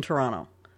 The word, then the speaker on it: Toronto: There is no second T in Toronto.